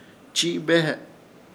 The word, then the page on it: Chiibehe: Ishir Chamacoco